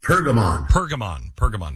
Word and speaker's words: Pergamon: It's called The Altar of, is it Perigmon? Perigmon? How do you say it? – Pergamon. – The Altar of Pergamon.